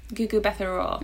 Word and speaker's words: Mbatha-Raw: I think you say the M, but I'm not sure. – Gugu Mbatha-Raw.